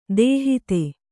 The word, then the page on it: ದೂಹತ್ತಿ: dūhatti